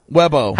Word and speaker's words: Webbo: Webbo. – How do you spell that? – W-E-B-B-O.